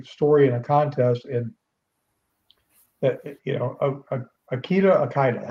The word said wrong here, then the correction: Okada: how do you say that? – Okada.